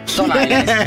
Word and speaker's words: Stone Island: Questi jeans della Stone Islands. – Stone Island. – Ma perché scrivono? Scrivono come si legge. – Si chiama così, Stone Island.